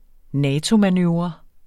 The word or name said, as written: NATO-manøvre